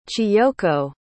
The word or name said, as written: chiyoko